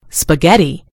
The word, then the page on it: spaghetti: British English